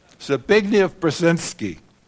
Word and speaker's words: Zbigniew Brzezinski: Zbigniew Brzezinski.